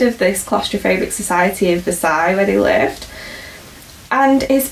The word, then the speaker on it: Versailles: Versailles. – I was doing so well with my pronunciation, Versailles.